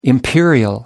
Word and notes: imperial: American English

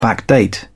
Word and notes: backdate: British English

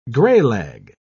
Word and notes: graylag: British English

